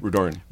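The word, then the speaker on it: Rudoren: One was a recent, how do you say Jody's last name? – Rudorin.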